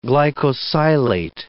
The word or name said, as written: glycosylate